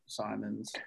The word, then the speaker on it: Simons: How do you pronounce that guy? – They just paid Anfernee. Yeah, you got it, Simons.